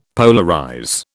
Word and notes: polarize: British English

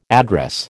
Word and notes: address: American English

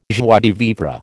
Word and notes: joie de vivre: American English

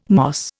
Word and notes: moss: Indian

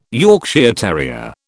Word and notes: Yorkshire terrier: British English

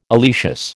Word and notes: oleaceous: American English

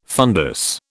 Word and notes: fundus: British English

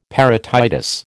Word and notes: parotitis: American English

